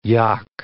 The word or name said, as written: yock